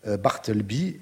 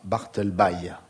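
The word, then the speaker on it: Bartleby: Ainsi, il faut dire que beaucoup d'auditeurs précisent à Arnaud et Olivia que Barthelby se prononce avec un I prononcé, ajoute Vincent Poineau, Barthelby et non pas Barthelby.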